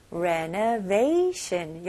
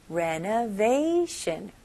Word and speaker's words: renovation: 네, 발음은 앞부분만 다르고 마지막 부분은 비슷해요. renovate 그 다음에 sh 발음으로 shun. renovation. renovation. 여기는 강세가 어디에 들어가죠? 네, they 부분에 좀 강세 주시면 좋아요. renovation.